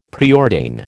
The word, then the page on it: preordain: American English